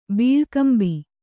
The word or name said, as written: ಬಿಳ್ಕುಂಬೆ